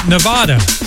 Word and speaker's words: Nevada: The state may be called Nevada, but the Swedish DJ producer pronounces his name Nevada, collaborating with the original singer of Return of the Mac, Mark Morrison.